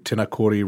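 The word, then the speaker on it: Tinakori: But at 342 Tenakori Road, is that the correct pronunciation? – Yep.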